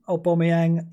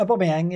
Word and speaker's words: Aubameyang: a lot of talk about Aubameyang. He's got to be. Did I pronounce that correctly? A lot of people saying I'm pronouncing Aubameyang wrong. – That was right. That was it. – Yeah, Aubameyang.